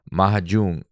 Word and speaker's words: Mahjong: Junko Furuta was tortured for 44 days in the home of her classmate and then was murdered for beating her captors in Majung. Mahjong, I think the H is silent, is a Japanese game, some Japanese game.